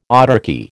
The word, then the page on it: autarky: American English